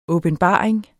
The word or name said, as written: åbenbaring